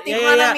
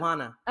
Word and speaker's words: Tijuana: It's because I noticed that Americans call it Tijuana or Tijuana. – How do you say it? – Like your Tijuana. – Tijuana. Talked about. – Yeah. – Tijuana. – I'm like, it's literally Tijuana. It's not Tijuana.